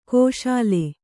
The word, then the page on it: ಖುಶಾಲ್: Kannada